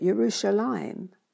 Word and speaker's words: Yerushalayim: U-R-U-S-A-L-I-M, Jerusalem, meaning the city of Salem. In Hebrew, it is said Yerushalayim, and that's spelt differently again, Y-E-R-U-S-H-A-L-A-Y-I-M, Yerushalayim. Jerusalem is mentioned again when Joshua led the children of Israel into the Holy Land to take hold of God's promises.